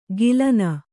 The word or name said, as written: ಗಿಲನ